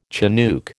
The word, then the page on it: chinook: American English